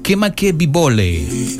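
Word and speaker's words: Kemakebibole (Kamakawiwoʻole): Un día como hoy, 20 de mayo de 1959, nacía en Honolulu, Hawái, Estados Unidos, Israel Kamakawiwole. Así como escuchan, chiquillos, tan difícil el apellido, Kamakawiwole. Aquí dice que se pronuncia Kemakebibole.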